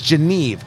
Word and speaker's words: Genève: I'm Tony Katz, and that right there is America's favorite amateur drinker, Fingers Malloy, and this right here, the Cavalier Genevieve. Or is it Genève? Not Genevieve, it's Genève. I pronounced it wrong.